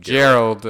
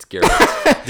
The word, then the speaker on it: Geralt: Gerald. – It's Geralt.